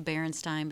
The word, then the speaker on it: Berenstain: We were talking about the Berenstain Bears. – Oh, Berenstain. – Stain. And I keep saying it wrong.